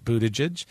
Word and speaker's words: Buttigieg: So it's Biden, Sanders, Harris, Buttigieg, in case you wondered how to say his name, Pete Buttigieg, Elizabeth Warren, Beto O'Rourke, Cory Booker, Klobuchar, Yang, Castro, Hickenlooper, that's not going to go anywhere, and Gillibrand.